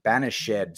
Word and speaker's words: banished: I haven't heard the word banished pronounced banished since Romeo and Juliet.